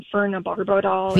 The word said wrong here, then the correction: phenobarbital: Phenobarbital. Yep.